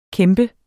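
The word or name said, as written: kæmpe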